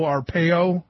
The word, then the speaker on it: Arpaio: Did you see the story out of Arizona, the Arizona Republic, the Maricopa County, the big sheriff there, Joe Arpaio, I think that's how you pronounce his name.